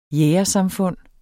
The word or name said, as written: jægersamfund